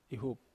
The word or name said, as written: hup